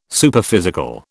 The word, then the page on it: Superphysical: British English